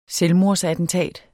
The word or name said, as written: selvmordsattentat